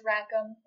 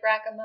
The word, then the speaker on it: Rackham: No, it's Rackham. – Rackham? – Like Rackham up.